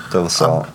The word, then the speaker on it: De La Salle: Oh, De La Salle. Is that how you say it? Spartans. – Yeah. – De La Salle.